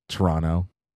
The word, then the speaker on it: Toronto: For our listener, Sonia Missio, we know it's pronounced Toronto. – What? Oh, I, I said the T.